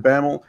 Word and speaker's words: Bammel: He is Coach David Bammel. I'm saying that right, Coach Bammel.